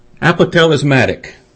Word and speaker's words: apotelesmatic: The word is apotelesmatic. I'll spell it for you. A-P-O-T-E-L-E-S-M-A-T-I-C.